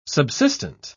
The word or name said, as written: subsistent